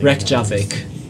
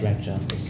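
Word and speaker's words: Reykjavík: Reykjavik. – Yeah, it's Reykjavik.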